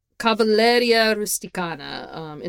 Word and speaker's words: Cavalleria Rusticana: Cavalleria Rusticana, intermezzo by Mascagni, which is not a piece that I had listened to myself, but apparently my dad really loved this song.